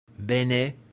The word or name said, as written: bene